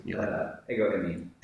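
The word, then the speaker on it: Ego emi (ἐγώ εἰμι): This passage is ego ami. Am I saying that correctly? – Ego emi. Ego emi.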